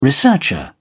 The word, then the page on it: researcher: British English